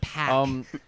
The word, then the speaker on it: Paak: we have been pronouncing Anderson's name wrong for a very long time. I have also been doing the same thing. It's Pack, not Pack. – Paak. – That's what the two A's is, Pack.